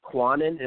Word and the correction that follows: QAnon: Well, I mean, QAnon. – I guess, yeah, QAnon as in like anonymous, because the whole thing started in the 4chan and then 8chan comms.